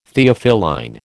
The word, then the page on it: Theophylline: American English